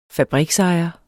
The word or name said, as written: fabriksejer